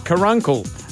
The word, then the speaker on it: caruncle: Lacrimical carungles. – That is not how you say it, I'm sure. – Caruncle. – What? – A lacrimal caruncle.